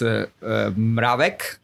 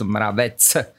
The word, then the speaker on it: Mravec: Look, in English or in Australia, it's Mravec. In Slovakia, where I come from, it's Mravec. But in English, it's really hard to pronounce that.